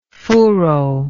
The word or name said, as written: furo